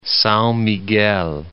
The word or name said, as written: Sao Miguel